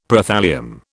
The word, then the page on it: Prothallium: British English